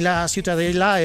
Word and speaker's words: Ciutadella: Para mí la Ciutadella es... ¿cómo se pronuncia en La Ciutadella. – Ciutadella. Ciutadella. Bueno, pues Ciudadela también en castellano.